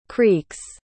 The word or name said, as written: creek's